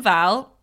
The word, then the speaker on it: Wall: Kim Val, it's spelt Wall, but it was pronounced Val quite a lot.